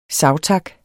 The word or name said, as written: savtak